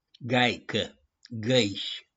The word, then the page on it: GAICĂ: GĂIȘI